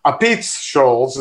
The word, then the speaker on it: Apizza Scholls: Apizza Scholls is really the proper pronunciation of that. – Thank you for that. – Well, that's the way a New Havener would pronounce it. I don't know if Brian does. I don't think he does.